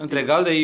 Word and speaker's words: Intregalde: I think Intregalde. You you just pronounce it very well. Okay, that's because the I is a bit different, no? Than, yeah, yeah, yeah, yeah.